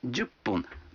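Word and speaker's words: джуппун (十分): А 10 минут – джуппун. Вы заметили, что когда фун добавляется к 10, дю, то произносится пун? – Послушайте и повторите каждую фразу.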